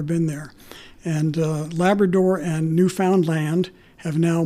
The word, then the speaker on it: Newfoundland: We were told ahead of time, you always pronounce land at the end. It's Newfoundland or Newfoundland. Either okay. one is acceptable, but locals, not Newfoundland.